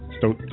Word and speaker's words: Stoughton: I love how they have the spelling so you know how to say it. Stoughton.